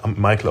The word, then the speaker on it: Michael: Und ich bin, also mein Name wird Michael geschrieben, aber wird Michael ausgesprochen.